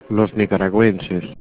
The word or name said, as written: nicaragüenses